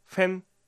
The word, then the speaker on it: 分: 日本語と似ていて、ふんを中国語ではフェンと発音します。日本語よりももっと口を横に開く感じです。